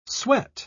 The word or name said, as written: sweat